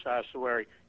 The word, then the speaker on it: ossuary: But there was a show on PBS several weeks ago about this ossuary. Am I pronouncing that correctly? – Yes, sir.